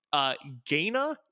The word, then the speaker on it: Gaina: Gaina? G-A-I-N-A?